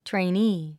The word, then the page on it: trainee: treiníː; トレイニィ